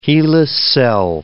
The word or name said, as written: hela cell